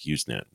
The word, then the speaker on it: Usenet: which is pronounced with the S should be a Z sound, I think, Usenet.